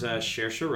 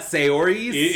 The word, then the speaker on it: Saoirse: And it stars Saoirse Ronan. Yes, that is how you pronounce that name. – Oh, it's not Saori's? – It's not Saori's.